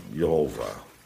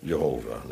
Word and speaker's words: Jehovah: Zoals hier staat daar de naam Jehovah. Dat is de klassieke antieke uitspraak. En daar houd ik het maar op. Jehovah, dan weet iedereen waar het over gaat.